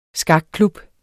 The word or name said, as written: skakklub